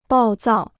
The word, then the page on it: 暴躁: bào zào